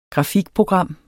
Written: grafikprogram

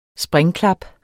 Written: springklap

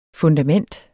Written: FUNDAMENTERS

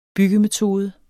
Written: byggemetode

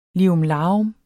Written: lirumlarum

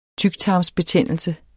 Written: tyktarmsbetændelse

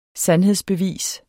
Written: sandhedsbevis

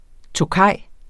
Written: tokayer

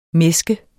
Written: mæsk

